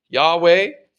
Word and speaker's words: Yahweh: This is often translated Lord, all capitals in your Bible, and it's commonly pronounced Yahweh today. Although we're not quite sure how it was pronounced because ancient Jews didn't pronounce the name of God.